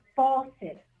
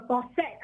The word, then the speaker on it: Fauset: It's spelled, I want to spell it right because I could be saying it wrong, but it sounds like Fauset. Um, her last name is spelled F-A-U-S-E-T. So it could be Fauset or Fauset. I'm going to say Fauset because it sounds exotic.